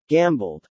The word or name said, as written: gambled